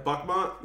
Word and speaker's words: Bakhmut: city of Bakhmut. Did I say that right? – Bakhmut.